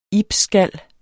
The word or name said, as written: ibsskal